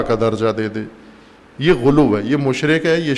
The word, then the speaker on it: غلو: غلو نہیں، غلو پنجابی تلفظ ہے، عربی تلفظ کریں غلو، سب کہیں غلو۔